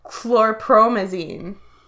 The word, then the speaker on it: chlorpromazine: She had a huge amount of chlorpromazine.